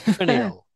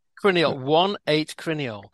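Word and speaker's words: 1,8-cineole: And have I pronounced that right? Crineal, 1,8-crineal. – Right.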